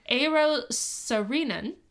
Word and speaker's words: Eero Saarinen: They hired Finnish-American architect, thank you, Leanne, for putting in the pronunciation, Eero Saarinen, who also designed St. Louis's...